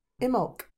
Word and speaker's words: Imbolc: Imbolc.